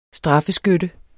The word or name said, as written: straffeskytte